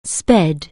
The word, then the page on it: speed: anglais